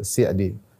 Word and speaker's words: Sa'di: Jadi eh, dibacanya bukan Sa'di tapi Sa'di sebagaimana yang eh, diucapkan oleh keluarga beliau dan yang kami dengar dari guru-guru kami ketika membaca nama beliau selalu dibaca dengan mengkasroh sin gitu Sa'di Ibnu Sa'di atau Syekh As-Sa'di rahimahullahu taala.